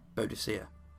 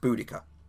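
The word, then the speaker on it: Boudicca: Just say Boudicea, and one of them goes, actually, it's pronounced Boudica.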